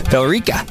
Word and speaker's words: Billerica: Bill Eric. It's Billerica? Bill? What? How do you pronounce it? Billerica. There's an E in there. – Billerica. – Billerica, maybe? – Billerica. – Oh, that makes sense. Just leave out the E.